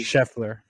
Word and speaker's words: Scheffler: Scotty Scheffler.